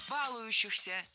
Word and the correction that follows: балующихся: Неверно. Правильно. Балующихся. Балующийся. В этом причастии, как и в глаголе, от которого оно образовано, ударение не ставим на корень. Баловать, балуешь, балующийся.